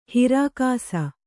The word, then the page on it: ಹಿರಾಕಸ: hirākasa